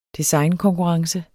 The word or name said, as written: designkonkurrence